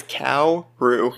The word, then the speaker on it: Kaoru: I learned that guy's name on purpose because I was so afraid I was going to say it wrong. It's Kaoru. – Yeah. Okay. – Like the animal.